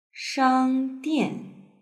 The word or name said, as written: shangdian